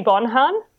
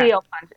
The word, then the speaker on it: Siobhan: The teacher goes, Siobhan, and no one answers. And she's like, Siobhan? And then this girl goes, is she Bonhan? – Oh, no.